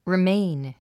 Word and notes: remain: riméin; リメイン

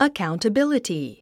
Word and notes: accountability: əkàuntəbíləti; アカァウンタビリティ

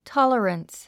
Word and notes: tolerance: tάlərəns; トォララァンス

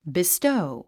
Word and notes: bestow: bistóu; ビストウ

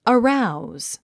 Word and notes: arouse: əráuz; アラウズ

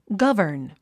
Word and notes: govern: ɡʌ́və r n; ガバン

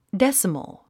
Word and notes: decimal: désəməl; デェスモォ

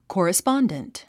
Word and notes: correspondent: kɔ̀ːrəspάndənt; コーラスパンデント

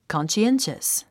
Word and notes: conscientious: kὰnʃiénʃəs; カンスィエンシャス

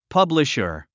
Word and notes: publisher: pʌ’bliʃə r; パブリシャァ

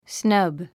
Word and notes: snub: snʌ́b; スナァブ

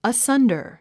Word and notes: asunder: əsʌ́ndər; アッサンダァ

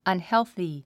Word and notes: unhealthy: ʌnhélθi; アンヘルスィ

